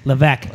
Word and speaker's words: Levesque: Patrick Levesque. I'm saying that right, right? – Yeah. I mean, you know, in the United States, you usually pronounce the S, but it's Levesque. – Levesque.